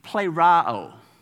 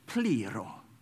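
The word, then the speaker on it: plero (πληρόω): accent, you would say it like this, plerao. And if you're Greek, or wanting to put on a Greek accent, it's plero. That's how you say it, if you want to sound fancy.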